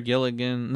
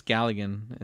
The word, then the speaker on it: Galligan: Galligan or Gilligan? No, it's Galligan. It's pronounced a certain way.